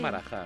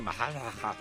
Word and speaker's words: Maharajá: A ver, los castellanos siempre pronunciamos mal todo, con lo cual, pues a lo mejor se dice Maharajá. – Maharajá. – Pero aquí decimos o Maharajá o Marajá.